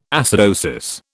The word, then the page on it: acidosis: British English